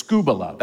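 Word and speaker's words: skubala (σκύβαλα): It's skubala. That's how you say it.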